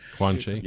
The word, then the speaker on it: Guanxi: Guanxi. – Yeah. – Guanxi. Yeah, so it's spelled G-U-A-N-X-I.